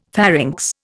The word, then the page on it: pharynx: Indian